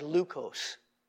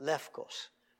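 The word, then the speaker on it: lefkos (λευκός): It looks like leukos, but modern Greek would pronounce it lefkos.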